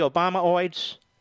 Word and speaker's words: Obamaoids: You notice the Obamaoids, which rhymes with hemorrhoids, like Clintonoids.